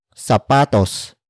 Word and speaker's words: sapatos: Word for word it's mamimili ng sapatos si Christian mamayang gabi.